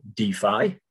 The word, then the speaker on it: DeFi: Tom did pronounce the term correctly, DeFi.